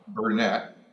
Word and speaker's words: Bernet: Bernet, B-E-R-N-E-T.